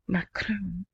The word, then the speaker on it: Macron: Okay, here, an upcoming visit by Macron, that's how you have to say his name, Macron, to Baghdad.